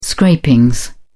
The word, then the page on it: scrapings: British English